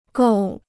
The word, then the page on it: 够: gòu